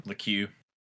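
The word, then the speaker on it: Lecque: Jalen Leck. Is that his name? Jalen LeQue Leck? I don't know how you say his name. – LeQue. – Oh. Jalen LeQue, I think he went ham.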